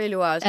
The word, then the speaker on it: Eloise: Yeah, so I'm forgetting, because if there wasn't the little accent on the I in E, it would be Eloise, but it's E.